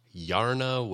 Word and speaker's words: Yarna: This band, next band, is from Peterborough, Ontario, and they're called Yarna Waves. I had to Google how to pronounce that. The word is Yarna, which is the Swedish word for brain.